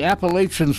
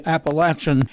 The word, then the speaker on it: Appalachian: Oh, I know, it's supposed to be Appalachian. I don't buy that. I feel that so the Southerners pronounce it Appalachian, and we have always pronounced it Appalachian, so I'll stand by that.